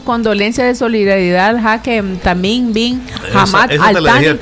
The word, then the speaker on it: Tamim Bin Hamad Al Thani: Ay, no me entendiste, que es Su Alteza Jeque Tamim Bin Hamad Al Thani Emir del Qatar.